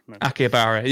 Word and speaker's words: Akihabara: Akihabara. – Yeah, that's it. It is Akihabara.